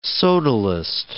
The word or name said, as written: sodalist